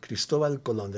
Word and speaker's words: Cristóbal Colón: So, Colón. Cristóbal Colón. That's how you say it in Spanish.